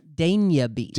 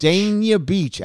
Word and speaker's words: Dania Beach: You actually have the pronunciation right on the, what is it? Go ahead. – Dania Beach. – Dania Beach. Alex says he got it from the mayor of Dania Beach.